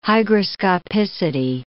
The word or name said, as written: hygroscopicity